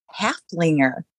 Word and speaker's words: Haflinger: Oh, and did you notice that I said Huflinger, correct? – I just did. I was just going to say something about that. I'm like, Huflinger. So yes, we have been corrected on our pronunciation of Haflinger.